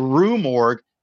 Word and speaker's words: Rue Morgue: He's Augustin Dupont, which, again, if you're a Poe fan, the Duke Morgue, the murders at the Dude Morgue, the, you never say this right, at the Rue Morgue.